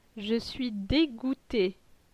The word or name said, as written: Je suis degoute